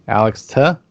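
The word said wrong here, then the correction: Tse: Say. – Alex Say.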